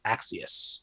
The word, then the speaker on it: Axios: he said, nope, we say it like Axios.